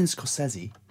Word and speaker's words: Scorsese: I don't want to spoil it for you, but Martin Scorsese is directing. That is how you say it. – Scorsese? – It's what he says in Entourage.